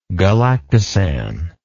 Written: galactosan

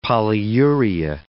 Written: polyuria

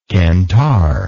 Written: cantar

